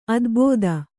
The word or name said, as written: ಅದೆಬದೆ